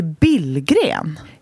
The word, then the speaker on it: Billgren: För det är inte hur vi uttalar vårt efternamn. – Nej. – Nej, hur ska alla du uttala säger det? Billgren. – Ja. – Vi heter ju Billgren.